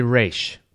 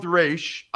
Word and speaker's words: Raiche: I think it might be French, but I don't know how to pronounce it yet. It's R-A-I-C-H-E. Try to give it some French flavor.